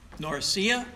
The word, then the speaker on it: Norcia: Norcia is the way that's pronounced.